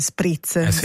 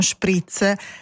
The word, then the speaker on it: spritz: Il più diffuso, accettato in italiano standard è spritz, detto proprio all'italiana. – Vero. – Se lo pronunciate alla tedesca, quindi vorrei uno spritz, è praticamente un calco esatto dal tedesco, forse un po' forzato, no Sergio?